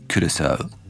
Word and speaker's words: Curaçao: Wzoruję się na ekspercie telewizyjnym Curaçao.